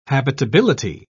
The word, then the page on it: habitability: British English